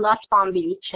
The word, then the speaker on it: Nussbaum-Beach: For those of you who don't know me, my name is Sheryl Nussbaum-Beach, and often when I say my name, especially in online environments or on the phone, people will often think that I say I'm Sheryl from West Palm Beach.